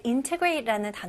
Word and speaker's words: integrate: 네, integrate 같은 단어는 일상에서는 많이 듣지는 못하고 주로 business 아니면 어떤 제품을 소개할 때 아니면 어떤 제품이나 서비스를 막 홍보할 때 integrate라는 단어를 많이 쓰거든요. Okay, integrate. 통합하다 라는 말입니다. 네, 발음 연습해 볼게요. Integrate. Integrate. Integrate. 네, 그래서 강세가 어디에 들어가죠? 네, 첫 번째 음절이죠. Integrate.